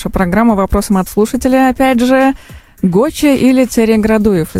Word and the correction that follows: Цареградцев: Извиняюсь, если ударение. – Цареградцев.